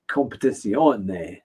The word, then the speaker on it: Competizione: I mean, particularly in the preview video, you announced it, pronounced it as competition instead of uh, competizione.